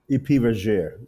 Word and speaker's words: Priviger: Priviger. It's French.